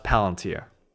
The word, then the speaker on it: Palantir: How do you pronounce it? Palantir. – Palantir, yeah. – I thought it was Palantir. – I think. Well, I had some friends who worked there, and I actually applied, and I got rejected. – Really? Oh shoot! I didn't know that. – Yeah. I did get rejected, but they've told me it's pronounced Palantir.